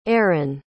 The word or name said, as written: ehren